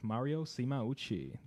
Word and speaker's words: Mario Simauchi: On our show this evening, we'll be speaking with Mario Simauchi.